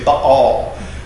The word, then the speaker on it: Baal: It is to demonstrate the impotence of, and by the way, I know I've said Baal to this point, I will now say Baal. That's how it would be pronounced.